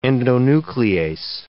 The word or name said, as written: endonuclease